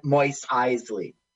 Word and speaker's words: Mos Eisley: That's her name, not Mo- Mos Eisley.